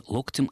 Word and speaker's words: локтем: Как правильно ставить ударение в творительном падеже единственного числа слова «локоть»? Локтем или локтем?